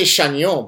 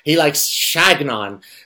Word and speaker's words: Chagnon: With his whole, I say Chagnon, but apparently he doesn't like when you pronounce it the French way. He likes Chagnon.